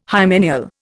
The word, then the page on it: hymeneal: Indian